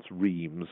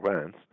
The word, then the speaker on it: Reims: I can recall some years ago going to give a lecture at what we spell and pronounce Reims and the French call Reims.